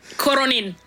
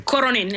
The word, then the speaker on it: Coronin: Coronin. It's Italian. – Okay. – Coronin. It's very typical Coronin. Of the Northeast.